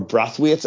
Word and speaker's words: Brathwaite: Martin Braithwaite, or Brathwaite, as I think is the correct pronunciation, has become kind of a joke figure because Barcelona signed him from Levante.